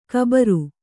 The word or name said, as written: ಘಾಬರಿ